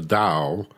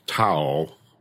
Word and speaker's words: Tao: And Taoism is no different in that regard, where the Tao, it's pronounced like D like David, O-W, Tao, but it's spelled T like Tom, A-O. Looks like Tao, pronounced Tao.